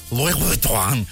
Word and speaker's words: Louis Vuitton: Louis Vuitton.